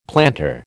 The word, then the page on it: plantar: American English